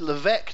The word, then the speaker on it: Levesque: I think you pronounce it Levesque, do you not? You put an S in it that's not said.